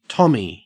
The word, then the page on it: tommy: Australian English